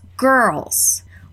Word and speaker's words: girls: We have a couple of linking sounds and a tough word to pronounce. It's girls. Let's elongate that word when we repeat. Girls. It's okay to do an exaggerated pronunciation of that until you can do it naturally.